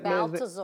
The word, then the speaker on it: Balthazar: How do you say that Balthazar. – Man? That's one of the guys, one of the three men that came to see Jesus. – Well, that's his name. – Yeah. – Okay. – Balthazar.